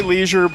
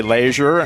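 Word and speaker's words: Leasure: The Roundheads were the 100th Pennsylvania Volunteers, formed by Colonel, we say Leisure, but the family who were here say Leisure and Lejeure, and many other ways to pronounce it.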